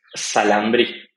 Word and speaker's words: Xalambrí: It's Xalambrí.